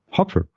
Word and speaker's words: Hooper: Ian H-O-O-P-E-R. Wie wird das ausgesprochen? Hopper. Hopper.